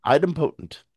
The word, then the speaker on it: idempotent: It is idempotent. Is that how you say it? Idempotent? – Idempotent. Idempotent Idempotent.